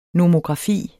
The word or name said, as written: nomografi